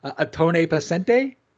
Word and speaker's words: Ottone Pesante: From The Doorway too, hanging out with Paolo and Francisco from, let me try and say it right, Ottone Pesante? – Yeah.